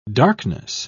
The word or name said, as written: darkness